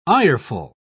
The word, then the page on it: ireful: British English